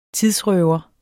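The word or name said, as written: TIDRØVER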